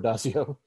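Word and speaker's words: Addazio: The coaching search was interesting for Adazio.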